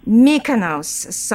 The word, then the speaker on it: Mykonos: Mykonos.